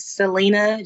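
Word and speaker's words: Selina: My Twitter is Selena J, and it's spelled S-E-L-I-N-A-A-J.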